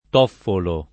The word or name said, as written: Toffolo